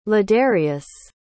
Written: ladarius

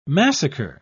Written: massacre

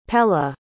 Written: pella

